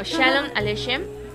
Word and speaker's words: Shalom Aleichem: No, Shalom Aleichem.